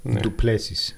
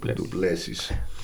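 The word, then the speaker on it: Du Plessis: Ωραία, πάμε στο επόμενο αγώνα που είναι ο Τιλ Πώς τον προφέρουμε? – Τιλ, Όχι τον Τίλ ρε, τον άλλο ρε. – Είναι τράικους Ντουπλέσις. – Ντουπλές. – Ντουπλέσις. Ντουπλέσις.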